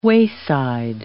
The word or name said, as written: wayside